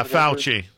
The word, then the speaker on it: Fauci: Fauci? – Yeah, Fauci.